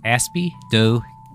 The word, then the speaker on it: Aspidochelone: Well, with some help from Loki, I have figured out that it is pronounced Aspidochelone. – Aspidochelone. Thought it was Lone. – Yes, Aspidochelone. Aspidochelone.